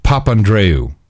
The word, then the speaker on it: Papandreou: How do you even pronounce his last name? George Papandreou. There you go.